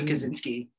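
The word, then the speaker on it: Kosinski: Top Gun Maverick director Kaczynski. Joseph Kaczynski.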